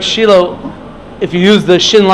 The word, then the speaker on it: Shiloh: But it's always pronounced Shiloh, it's just a question of orthographics, how exactly you spell it.